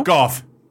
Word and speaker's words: Gough: G-O-U-G-H. Pronounce it. – Go. – Wrong. – No? – Goff. – There you go. – G-O-U-G-H? – Goff.